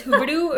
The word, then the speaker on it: Huberdeau: I don't know if you actually say his name is Huberdeau or Huberu, but when I called him, I was like, yo, like, do you say it like Huberdeau? And he's like, yeah, it sounds like a D, but it's spelt like E-R-E-A-U.